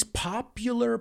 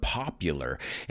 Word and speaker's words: popular: And uh, I said these popular party palaces. Now, careful with this word popular. He oído mucha gente decir popular, and it's popular. The U is pronounced like the letter U.